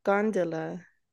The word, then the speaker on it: gondola: And the last time I was saying Galandaga. So I'm like, I don't think that's right. I could see you were like really making sure you were saying it right. – Gondola. Gondola. There you go.